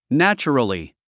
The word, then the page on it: naturally: nǽtʃ ə rəli; ナチュラリィ